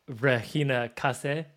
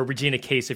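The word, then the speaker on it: Casé: Like, and Regina Casse, or Regina Case, if you're looking at it phonetically, if you're wondering who I'm talking about, is unbelievable.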